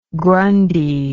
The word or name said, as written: grundy